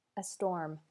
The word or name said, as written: a storm